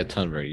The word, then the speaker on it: Thunberg: Yeah, there's been a lot of backlash against common agricultural policy, especially in like Greta Thunberg actually, is that how you say it? – Yeah, Tunberg, yeah, no.